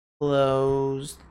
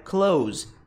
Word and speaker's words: clothes: Putz, é clothes. Clothes. Não, é close. Close. Como se fosse o verbo fechar, tá? Close. É a mesma pronúncia.